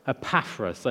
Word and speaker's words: Epaphras: Paul's friend, Epaphras, I'm probably saying that wrong. How would you say it? – Epaphras, I knew. I've even got it written out in different ways to try and get it right. Epaphras, let's do that.